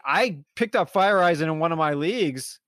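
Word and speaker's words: Feyereisen: Fire Eyes, and I think that's how you pronounce it. I'm not trying to say it wrong. – I believe that is how you pronounce it, yeah.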